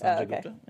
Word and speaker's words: Sanjay Gupta: Yes, Sanjay Gupta.